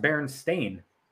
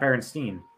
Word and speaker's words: Berenstain: It's pronounced Berenstain, not Berenstein.